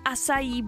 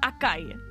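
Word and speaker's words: açaí: Asaj nebo asají bowl a ne akaj. Někdo říká akaj bowl, tak je to asají.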